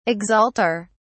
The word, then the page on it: exalter: British English